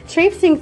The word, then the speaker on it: traipsing: After plodding uphill for many weeks, you are now trapezing traipsing, I've said that wrong, sorry, traipsing through lush meadows drenched in warm sunshine.